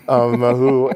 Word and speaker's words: Mahu: and she asked if you were Mahu. I'm so glad that I pronounced it properly, Mahu, and tell the folks what that means.